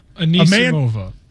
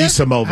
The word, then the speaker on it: Anisimova: Anisimova. – Anisimova. – How do you say it? – Anisimova.